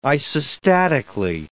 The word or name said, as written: isostatically